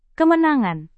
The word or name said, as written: kemenangan